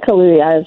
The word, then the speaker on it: Kaluuya: And how do we say this guy's name? Daniel Kaluuya? – I think Kaluuya is right.